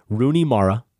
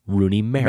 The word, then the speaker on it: Rooney Mara: In direct response to the email we read from Lisa Iannucci last week in this very segment, chastising us for our pronunciation, our incorrect pronunciation of the actress Rooney Mara, who she says is correctly Rooney Mara.